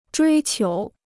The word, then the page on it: 追求: zhuī qiú